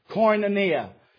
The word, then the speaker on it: koinonia: And it comes from a Greek word that is pronounced koinonia.